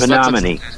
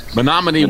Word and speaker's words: Menominee: What's the Menominee. – Menominee, Wisconsin.